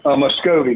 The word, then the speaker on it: Muscovy: Is it a Muscovy duck? Is that correct? – Muscovy, that's correct.